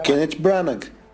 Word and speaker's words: Kenneth Branagh: Kenneth Branagh.